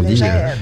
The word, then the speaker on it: Léger: Hein, qu'on légère. – Dit, ça s'écrit léger, mais ça se prononce légère. D'ailleurs, il n'y a pas d'accent sur le premier E.